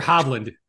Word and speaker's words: Hovland: Victor Hovland.